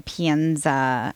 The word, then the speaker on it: Pienza: And I do want to get into the Pienza.